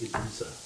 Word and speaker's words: Ibiza: I believe it's pronounced Ibiza.